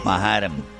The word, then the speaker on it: Manhattan: Manhattan. – ¿En dónde? – En Manhattan. Estaba en Manhattan. – Manhattan, vamos. – No se dice así en inglés, pero está bien.